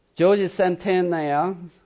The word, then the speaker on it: Jorge Santayana: Jorge Santayana, who was a Spaniard, did I pronounce it correctly? – Yes.